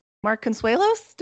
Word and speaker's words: Mark Consuelos: Mark Consuelos? Did I get his name right? – Yep. Yes. You got his name right.